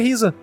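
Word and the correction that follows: Ryza: Raiza, no caso, né? A pronúncia correta.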